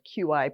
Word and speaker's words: qi: It's spelled Q-I, pronounced qi.